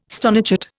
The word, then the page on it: stonechat: Indian